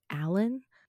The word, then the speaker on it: Allynne: Is it Aline? I'm not sure how to pronounce her last name. – Hello, to say that it's actually Stephanie Allen, just for the record.